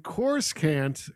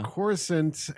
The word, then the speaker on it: Coruscant: What saying that I was pronouncing Coruscant completely wrong, and it's Coruscant, and you know, stop saying it wrong.